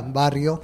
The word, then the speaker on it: barrio: So, how do I pronounce barrio? – Yeah, barrio. – Barrio. Yeah. Okay, barrio. – Barrio. – I took Spanish in high school. I got the rolling of the R's down.